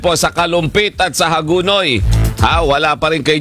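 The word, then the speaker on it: Hagonoy: Pero yung mga local doon, pati yung mga kakilala kong reporter na taga doon, ganun daw talaga pala ang pronunciation. Hagunoy. – Hagunoy.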